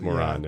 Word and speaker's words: Moran: It's M-O-R-A-N and it's pronounced moron. – Yeah, the Anglican way would be Moran, but you know, there you go.